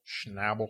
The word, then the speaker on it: Schnabel: This film was directed by Julian Schnabel. – Schnabel.